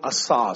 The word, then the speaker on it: asah: Because the word get is the Hebrew word asah. Somebody say after me, say asah. A-S-A-H. Now, that's not the way it's written in Hebrew, but that's the way it's written to be able to be pronounced.